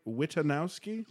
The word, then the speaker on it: Witanowski: We have Dave, am I pronouncing this right? Witanowski? – Yes. One of the few people actually who, including people in my own family, who have pronounced it correctly. A lot of us just say Witanowski, and I'm like, no, it is Witanowski.